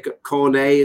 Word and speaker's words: Cornet: Everton might be no different, or should I say Cornet, as I imagine they would pronounce it there over in France, where he obviously came from the French league.